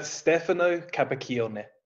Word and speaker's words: Capaccioni: So it's a Stefano Capaccioni. – Oh my gosh, there's a hard C in there.